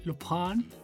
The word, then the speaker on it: Lupin: Pues bueno, esta recomendación es una serie que ya vimos los dos este fin de semana que es la de Lupin, que se pronuncia Lupin.